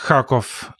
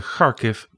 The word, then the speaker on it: Kharkiv: En dan was het altijd Kharkov en Kiev. En je gaat het net iets anders uitspreken, Kharkiv, Kiev.